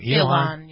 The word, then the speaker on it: Ilhan: Okay, speaking of stealing oxygen, number nine is Congresswoman Ilhan. Is that how you pronounce, how do you Ilhan. – Pronounce her name? – Ilhan. Yeah.